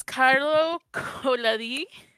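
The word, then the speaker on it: Carlo Collodi: So it was run by, I'm gonna butcher his name, It's Carlo Coladi. – Spot on.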